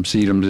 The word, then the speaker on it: Sedum: And one of my favorite ones is one of the many sedum. Sedum is a huge... – Spell that for me, would you? – S-E-D-U-M, like dumb.